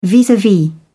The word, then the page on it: vis-a-vis: British English